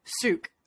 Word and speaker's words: Sucre: Like Art of Souk is how they pronounce it. There's no ruh at the end of it.